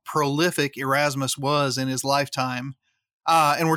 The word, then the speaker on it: Erasmus: You were on the Partially Examined Life Philosophy podcast, and you were talking about the Dutch philosopher Erasmus. Am I saying that correctly? – Yep, Desiderius Erasmus.